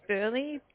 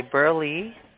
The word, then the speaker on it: Burleigh: Yeah, so it's Burley, B-U-R-L-E-I-G-H. – Okay, Burley.